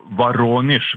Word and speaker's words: Voronezh: Jan Espen Kruse, direkte med fra, det Voronezh eller Voronezh, hvordan sier du det? – Ja, Voronezh, hvis du skal være veldig pirkete, så har du fått riktig uttale.